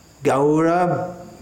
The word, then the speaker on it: Gaura: Shivasadi, not Shivasadi, Shivasadi, Gaura Bhaktavrinda, Gaura, Gaura, this sound, A-U, is pronounced something like the sound in how, H-O-W, how, Gaura, Gaura.